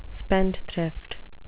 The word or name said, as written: spendthrift